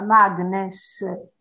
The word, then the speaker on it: Magnes: Leo Magnes, scritto Magnes, ma siccome era un ebreo di origine tedesca si pronuncia Magnes.